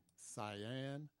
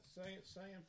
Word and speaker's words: Sian: And her name again, I don't want to mess it up, so I'm going to spell it S-I-A-N, Cyan Proctor. – Yeah, Cyan Proctor.